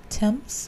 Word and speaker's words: Thames: The, how was it pronounced again? – Thames. – The Thames was dredged and six more bodies were found, including Doris and Harry.